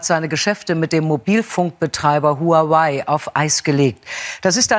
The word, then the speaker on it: Huawei: Huawei, drei Silben.